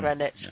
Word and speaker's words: Greenwich: from Greenwich Village. – Greenwich. Oh, is that how you pronounce it? – Yeah.